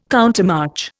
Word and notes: countermarch: Indian

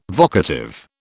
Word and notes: vocative: British English

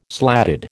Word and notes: slatted: American English

